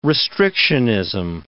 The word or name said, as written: restrictionism